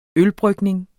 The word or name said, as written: ølbrygning